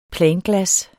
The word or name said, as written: planglas